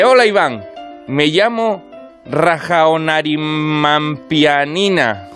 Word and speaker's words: Rajaonarimampianina: Rajaonarimampianina. – Ah, pues sí, le ha dado una sílaba más que yo me había comido.